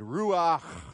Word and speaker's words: ruach: You pronounce it ruach. You have to get a sound in the back of your throat.